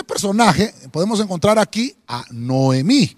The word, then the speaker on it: Noemí: Voy a escribirlo, voy a escribirlo porque a veces lo, ponemos con H, pero en esta, en esta versión eh, eh, solo dice Noemí sin H y lleva el acento en la I.